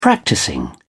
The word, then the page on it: practising: British English